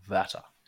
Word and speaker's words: Werther: It's not Young Werther, it's Young Vatter. – Yeah. – It's a German pronunciation.